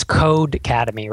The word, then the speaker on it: Codecademy: I mispronounced it earlier. It's Codecademy, right? – Yes.